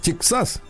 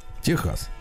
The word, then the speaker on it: Техас: Некоторые спрашивают, почему у американцев Тексас, а у нас Техас? Дело в том, что в испанском языке буква X читается как Х. Техас, да. – Так что мы правильно читаем.